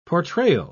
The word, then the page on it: portrayal: British English